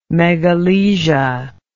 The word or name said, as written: megalesia